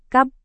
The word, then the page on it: kab: Indonesian